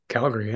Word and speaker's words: Calgary: Calgary, yeah. – Yeah. Okay. – How did I say it? Calgary? – Yeah.